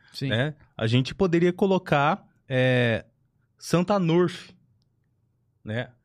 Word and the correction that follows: Arnulf: Ah, eu pronunciei certo? Arnulf. Ele, esse, esse Santa Arnulf, ele foi, ele é considerado padroeiro da cerveja, né?